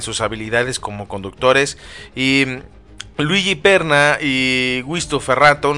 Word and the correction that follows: Ferronato: ¿no? Eh, perdón, Ferronato, es la, pronunciación correcta, que son periodistas, eh, ellos afirman que la noticia eh, pues es una realidad, ¿no?